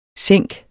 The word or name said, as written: sænk